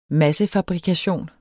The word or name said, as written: massefabrikation